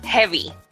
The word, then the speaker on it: jevi: Is the word heavy. – Heavy. – And it's not heavy as in heavy in English. – Okay. How would you spell that? – Different. Yeah. I would spell it J-E-V-I.